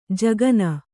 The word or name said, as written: ಝಗನೆ